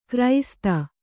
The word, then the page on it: ಗ್ರಸ್ತ: grasta